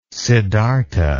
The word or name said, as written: Siddhartha